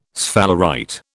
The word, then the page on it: sphalerite: British English